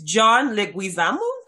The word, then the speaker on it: John Leguizamo: It's John Leguizamo. – So John Leguizamo.